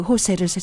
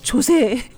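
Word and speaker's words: José: 옛날에 호세라는 남자가 있었어요. J-O-S-E. 제가 그 호세를 세상에 그걸 몰라서 조세, 조세, 조세 하고 부르다가 응?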